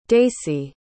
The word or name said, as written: deysi